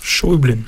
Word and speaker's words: Schäublin: Donc, Désordre, film du réalisateur suisse Cyril Schäublin, c'est comme ça qu'on prononce ? – Schäublin.